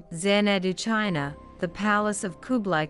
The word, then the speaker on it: Xanadu: The most remarkable of all, though, was Xanadu, pronounced Xanadu, to the palace of the great Khan.